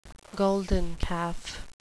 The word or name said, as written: golden calf